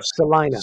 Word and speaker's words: Celina: Salina. – C-E-L-I-N-A. Yeah, pronounced Salina.